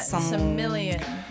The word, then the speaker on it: Silmarillion: Yeah, Silmarillion.